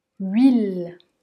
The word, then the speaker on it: huile: Ici, pour prononcer ce son, on part comme si on allait prononcer un U, mais on finit par prononcer un I. Oui, oui, huile, huile. Et on enchaîne avec le L, car le E n'est pas prononcé.